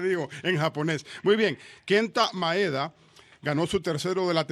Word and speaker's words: Kenta Maeda: El pitcher japonés, y lo quiero pronunciar bien, porque sin querer lo he pronunciado mal, Kenta Maeda. ¿Ok? Lo pronuncié bien esta vez.